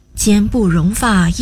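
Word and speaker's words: 间不容发: 奸不容发意思是距离极近，中间容不下一根头发，比喻离灾祸极近，情势危急到了极点。这里的奸指两者之间，不含有间隔的意思，所以不能读作奸，而是奸，两者之间容不下一根头发。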